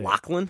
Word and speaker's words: Lachlan: Defeats Lashin Giles by decision. – You butchered his name. – How do you say Lachlan? – It? Lachlan. – Lachlan Giles? – Lachlan Giles. Okay.